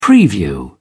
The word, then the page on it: preview: British English